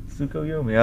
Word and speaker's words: Tsukuyomi: Tsukuyomi. I like how you said that. – Did I say it right? – Uh-huh.